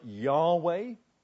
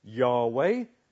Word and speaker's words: Yahweh: In the Hebrew, no one's quite sure how it's pronounced. But the word is probably something sounding like Yahweh.